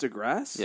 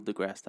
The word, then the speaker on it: deGrasse: It's pronounced deGrasse? – Yeah. Neil deGrasse Tyson.